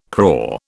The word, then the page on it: crore: British English